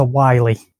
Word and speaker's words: Wily: Because I saw it and I read it as Mega Man the Wily Wars and then realised he's Dr. Wily. That's how you pronounce it. There's no two L's, there's one L.